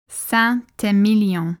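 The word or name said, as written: Saint-Émilion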